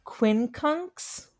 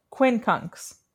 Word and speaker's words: quincunx: Quincunx? Did I say that right? – Yes. – Quincunx.